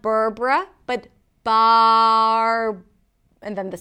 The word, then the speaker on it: Barbara: The next name is Barbara, Barbara. This name has two syllables. The first syllable is bar. Bar, start with a B sound, close your lips.